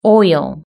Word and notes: oil: British English

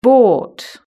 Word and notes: bought: British English

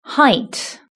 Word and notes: height: British English

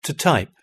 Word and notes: typing: British English

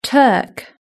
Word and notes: turk: British English

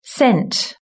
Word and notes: sent: British English

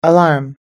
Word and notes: alarm: American English

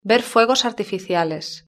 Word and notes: ver fuegos artificiales: European Spanish

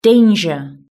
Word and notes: danger: British English

